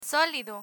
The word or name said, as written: solido